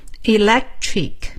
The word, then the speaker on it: electric: electric electric 中间我 发音停顿的那一下其实是一个 k 的发音但是呢因为它连起来这个音就发不出来了但是不能吞掉 electric electric 就这样出来的下面装饰品